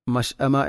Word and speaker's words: المشأمة: മഷ്അമ എന്ന പദത്തിൽ ഹംസയുടെ ഉച്ചാരണം വ്യക്തമാക്കണം. മഷ്അമ.